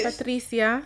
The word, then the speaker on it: Patricia: Hola, me llamo Patricia. – Hey, tengo. Is that how you say your name in Spanish? – Patricia. Hey. You know, got to give it a little bit of a roll.